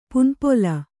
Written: ಪನಿಪುಲ್

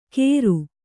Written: ಕೇರು

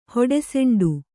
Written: ಹೊಡೆಸೆಂಡು

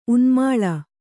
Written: ಉನ್ಮಾಳ

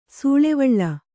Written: ಸೂಳೆವಳ್ಳ

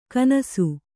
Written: ಘನಿಸು

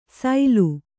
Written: ಸೈಲು

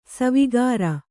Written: ಸಾವುಕಾರಿ

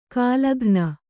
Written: ಘಲಕನೆ

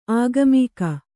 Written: ಆಗಾಮಿಕ

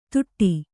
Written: ತುಟ್ಟಿ

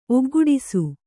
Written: ಒಗ್ಗೂಡಿಸು

